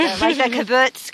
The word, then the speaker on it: kibbutz: I mean, I stereotypically just know of, like, from my Jewish literature class in college about the kibbutz kind of experience, right?